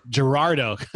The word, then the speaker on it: Girardeau: He also writes a column about entrepreneurship for B Magazine out of, okay, I forgot to ask you how to pronounce that, Cape Girardeau? – Girardeau. – I was giving it too much of a Cajun accent there.